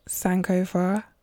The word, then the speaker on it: Sankofa: So before we begin, I just want to congratulate you on the release of your new book, Sankofa. Is that how you say it? – Yes, that's how I would say it.